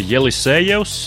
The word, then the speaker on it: Jelisejevs: Un, um, Atkal hokejists Nikolais eh, Jelisejevs. Jā, es izrunāju viņa uzvārdu pareizi. Izrādās, ka tieši tā viņa uzvārds ir rakstīts viņa Latvijas pilsoņa pasē. Nevis Jelisejevs, bet Jelisejevs.